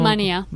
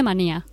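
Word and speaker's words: Wania: Manía. – Manía, ¿verdad? – Ahora se escribe con W, pero se dice Manía.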